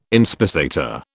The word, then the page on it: inspissator: British English